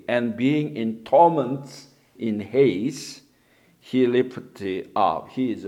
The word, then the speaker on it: Hades: Here, the heart of the earth in Hades, the Greek word Hades, but the English pronounced Hades, and Sheol, the Hebrew, translated in English, hell, H-E-L-L, or grave, before Jesus' resurrection.